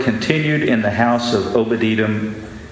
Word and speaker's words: Obed-Edom: Obed-Edom.